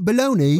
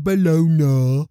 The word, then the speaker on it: bologna: Because Oscar Mayer has a way with B-O-L-O-G-N-A-E. – What? – E-E, baloney. – No, it's A. – Well, that's how you say it, baloney. – What are you doing? Two E's. – I don't know anyone that calls it balona, except Weird Al.